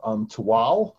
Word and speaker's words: Tehuatl: So we are doing a book called Tual, and it's actually spelled T-E-H-U-A-T-L. That's the pronunciation in Nual, which was the language that was mm-hmm. spoken in Mesoamerica in the 16th century.